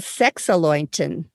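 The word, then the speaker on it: Sechseläuten: One is called Sexalointen, and it's spelled S-E-C-H-S-E-L-A-U-T-E-N. It's celebrated in Zurich in Switzerland, and there's this 12-foot-tall creature called the Boog, B-O-O-G-G.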